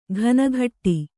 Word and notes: ಘನಘಟ್ಟಿ: Kannada

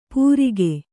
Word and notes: ಪೂರಿಗೆ: pūrige

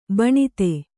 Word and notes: ಬಿಣ್ಣಿದ: biṇṇida; kannada

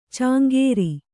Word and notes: ಚಾಂಗೇರಿ: cāŋgēri; Kannada

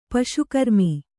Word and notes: ಪಶುಕರ್ಮ: paśu karma

